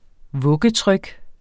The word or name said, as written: vuggetryk